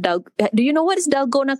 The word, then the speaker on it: Dalgona: Oh, you mean Delgado? Wait, no, I'm saying it wrong. – Delgado? – No, one with the foamy Dalgona coffee.